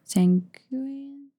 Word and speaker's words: sanguine: Sanguine. – Yeah, what is the context of that? – Sanguine. – Thank you. I said it right, just without confidence.